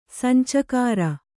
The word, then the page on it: ಸಂಚುಕಾರ: Kannada